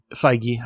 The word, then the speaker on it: Feige: And then so now Kevin Feige, right? – Feige. – I always say it wrong.